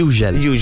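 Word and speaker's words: Hugel: Moving to the Sun, no, a parte scherzo, un DJ, come si pronuncia? – Francese, sì, Ugel.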